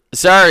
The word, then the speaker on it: Tsar: Tsar is, it starts with a T, but I think the T is silent.